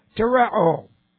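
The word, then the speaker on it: Tereo (τηρέω): T long E, R E long O. Tereo. Tereo means to guard, to obey, to believe.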